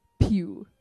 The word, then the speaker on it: Pugh: The correct pronunciation is Florence Pugh. That is how you pronounce it.